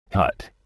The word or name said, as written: hut